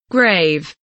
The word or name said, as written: grave